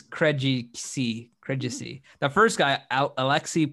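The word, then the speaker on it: Krejčí: Vit Krejci. – Okay. Krejci. It's Krejci.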